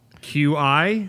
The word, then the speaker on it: Qi: QI? – Is that what we're talking about? Yeah, it's funny because I just found out that that's pronounced Qi right before this podcast because we were looking at articles and I was like, huh, go figure.